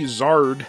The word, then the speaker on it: Xrd: That's how you pronounce XRD, Zard.